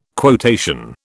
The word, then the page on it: quotation: British English